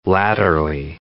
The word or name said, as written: latterly